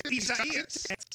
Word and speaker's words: Isaias: I-S-A-I-A-S. – And it's four syllables. I do know that. I-S-A-I-A-S. And it's like I-S-A-I-A-S.